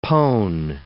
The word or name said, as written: pone